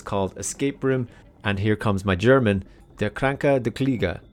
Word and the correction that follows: Der kranke Kollege: Der Kranke Kollege. – Thanks to Monica from Geek Herring for that correct pronunciation, which translates into the sick colleague.